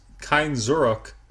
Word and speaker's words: Kein Zurück: But this one, like you were about to mention, features, and you had to let me know if I'm saying this wrong, Wolfsheim's Kein Zurück. Is that close? – Yeah, that's very close.